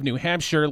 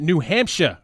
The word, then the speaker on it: Hampshire: Eric Gallagher, a Democrat from Concord, also wants to include an official pronunciation for New Hampshire, making it clear that S-H-I-R-E rhymes with fur and not fire.